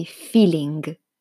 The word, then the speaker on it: feeling: Naturalmente l'italiano pronuncia anche la G finale, quindi feeling.